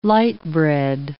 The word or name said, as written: light bread